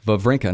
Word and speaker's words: Wawrinka: Even though I think I still said it wrong. Wawrinka. No, I think that's right.